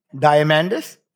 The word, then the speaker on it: Diamandis: I've known Peter for 7 years now, maybe, and I keep going back and forth with Diamandis, Diamandis. How the hell do you pronounce his name? – Diamandis.